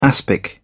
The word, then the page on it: aspic: British English